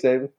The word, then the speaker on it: Saban: Did I say Nick Saban? – Yeah, you did. – Every time you said it right.